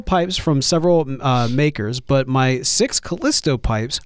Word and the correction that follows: Castello: Castello, my, Castello pipes, are my pride and joy.